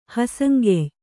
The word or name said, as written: ಹಸಂಗೆಯ್